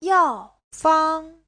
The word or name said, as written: yao4fang1